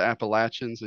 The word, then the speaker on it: Appalachians: I grew up in the foothills of the Appalachians, and yes, that's how it's pronounced.